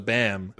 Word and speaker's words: balm: So when you pronounce the L, that makes it weird. – Balm. – A balm. – Wait, you want me to take out the L? It's a bam. That sweet, nice bam.